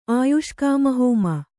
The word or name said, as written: ಆಯುಷ್ಕಾಮಹೋಮ